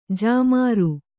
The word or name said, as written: ಜಾಮಾರು